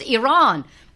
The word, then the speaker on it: Iran: Iran.